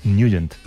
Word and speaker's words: Nugent: Hombre, siempre lo hemos llamado en el barrio Tednagent o Tednagent. – Claro, ¿no? Yo era de Tednagent. – Pero la pronunciación correcta es Nugent.